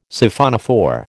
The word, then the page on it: Siphonophore: American English